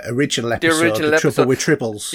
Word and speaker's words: Tribbles: Tribbles, yeah.